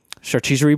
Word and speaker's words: charcuterie: One of them has a charcuterie board.